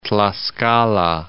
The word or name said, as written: Tlaxcala